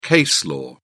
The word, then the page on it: case law: British English